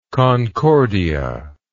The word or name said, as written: concordia